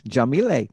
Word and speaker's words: Yamile: Oh, that's how you say it. Jamile. – Yes. – Okay. I've been pronouncing it wrong all these years or all these months.